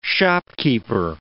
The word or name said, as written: shopkeeper